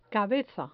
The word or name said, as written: cabeza